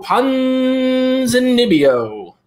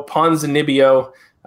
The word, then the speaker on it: Ponzinibbio: I'm going to go Santiago Ponzinibbio. – I think you got that time. I think you were right. – On how to pronounce his name? – Yeah, I think you got that one.